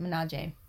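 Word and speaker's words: Milaje: So, another Black History moment is we were talking about Black Panther and how the Dora Mujale, I'm saying that wrong, Mujale, something like that. I'm butchering it. The arms guard. – Yes. – Dora Minaje. That's it, Minaje.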